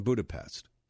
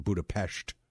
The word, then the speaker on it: Budapest: Now, it's written Budapest. It's actually pronounced Budapest. Budapest, Hungary.